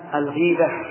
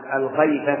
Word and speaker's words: الغيبة: الغيبة فعلة من الغيب، وليست كما ينطقه بعض الناس الغيبة بالفتح، هذا لحن، لحن مهيل للمعنى.